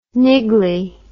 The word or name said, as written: niggly